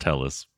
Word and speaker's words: Helles: Also, it is pronounced Helles, by the way. – It is German. Great. – You pronounce every syllable, yeah.